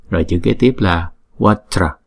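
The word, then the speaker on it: vajra: Chữ này là hai âm. Âm thứ nhất là Wa, âm thứ nhì là Tra. Chữ J này chúng ta đọc là Ja, Tra, Watra, Watra. Chữ này chúng ta đừng có đọc là qua chờ ra, là thành ba âm.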